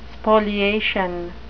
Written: spoliation

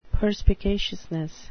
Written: perspicaciousness

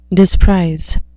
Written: disprize

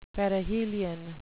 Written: perihelion

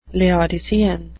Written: laodicean

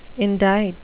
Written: indite

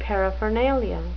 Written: paraphernalia

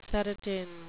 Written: serotine

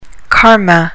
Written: karma

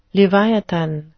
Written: leviathan

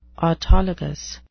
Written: autologous

